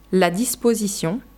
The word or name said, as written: la disposition